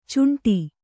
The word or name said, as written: ಚುಂಟಿ